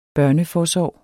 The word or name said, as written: børneforsorg